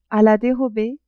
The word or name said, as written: a la derobee